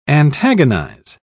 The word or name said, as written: antagonize